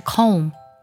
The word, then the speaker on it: comb: 梳头发呢是 comb，拼作 c o m b，这里 b 呢是不发音的。